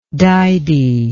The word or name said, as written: didy